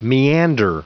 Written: meander

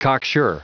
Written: cocksure

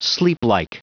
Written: sleeplike

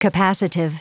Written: capacitive

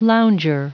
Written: lounger

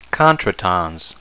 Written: contretemps

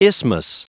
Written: isthmus

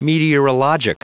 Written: meteorologic

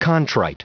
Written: contrite

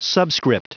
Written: subscript

